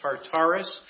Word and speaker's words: Tartarus: This is T-A-R. U.S., we usually pronounce this as Tartarus.